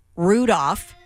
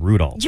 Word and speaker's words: Rudolph: Our boss came storming in moments ago, letting us know that you are so completely wrong because you're supposed to pronounce the L in Rudolph. – You're not.